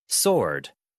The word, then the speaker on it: sword: Sword. Sword. She has a magic sword. She has a magic sword. – 네, 여러분. 이렇게 W도 묵음 유령 친구 될수 있다는 사실 꼭 기억해 주세요.